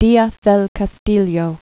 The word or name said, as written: Diaz del Castillo, Bernal